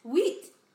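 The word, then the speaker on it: huit: Wheat. – That's French for 8. – Wheat. – Wheat. We had to look up the pronunciation of that one because it looked really hard to pronounce.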